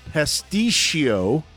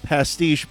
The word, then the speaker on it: pasticcio: Pasticcio, not pastiche, but pasticcio medley.